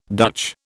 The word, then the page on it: Dutch: British English